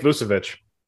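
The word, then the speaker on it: Vučević: How do you say his name? – Nick Vucevic.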